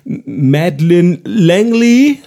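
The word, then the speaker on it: Madeleine L'Engle: Und zwar ist das von, es ist sehr französisch geschrieben, aber sie ist Amerikanerin und ich kann es so gar nicht aussprechen, weil die Amerikaner nennen sie Madeleine Langley, also Madeleine Langley geschrieben eigentlich und das Buch heißt Die Zeitfalte,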